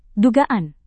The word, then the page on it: dugaan: indonesian